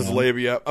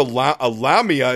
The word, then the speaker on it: labia: It's labia. I'm sure a lamia is a mythological creature.